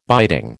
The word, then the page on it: biting: American English